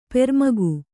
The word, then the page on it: ಪರಮುಖ: Kannada